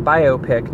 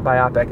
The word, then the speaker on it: biopic: One of the other buzzards pronounced it biopic out loud, and was like not rowdily, but enthusiastically judged for it, because it's a biopic, not biopic, and I was so stoked that I wasn't the one who said it, because I had only read it, right?